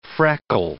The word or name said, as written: freckle